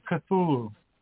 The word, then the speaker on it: Cthulhu: Shouldn't you call it the Cthulhos? Cthulhos? That sounded better in my head. – Okay. People usually pronounce it Cthulhu.